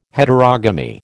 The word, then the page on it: Heterogamy: American English